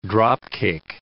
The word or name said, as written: dropkick